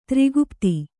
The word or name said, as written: ತ್ರಿಗುಪ್ತಿ